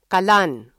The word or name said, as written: kalan